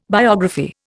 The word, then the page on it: biography: Indian